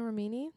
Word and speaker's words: Remini: Not Leah Remini? – Yeah, Remini.